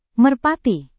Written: merpati

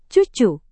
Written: cucu

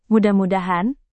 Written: mudah-mudahan

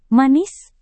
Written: manis

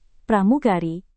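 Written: pramuka

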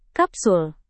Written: kapsul

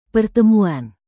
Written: pertemuan

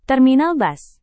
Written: terminal bus

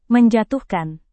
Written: menjatuhkan

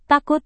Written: takut

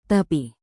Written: tapi

